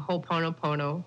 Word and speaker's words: Ho'oponopono: All right, so next, if you've got a family dispute and you'd like Harry to help you out by using Ho'oponopono. Did I say it right? – Yes.